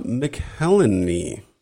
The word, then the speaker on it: McElhenney: So October 1st, 2008, Caitlin Olsen weds Rob McElhenney.